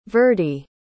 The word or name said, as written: verdie